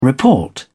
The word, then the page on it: report: British English